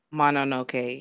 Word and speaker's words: Mononoke: So I'm going to give that another try, and I would like to watch, I'm going to watch Mononoke. Is that how it's pronounced? – Mononoke. Yes.